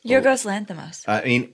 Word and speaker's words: Lanthimos: Yorgos Lanthimos.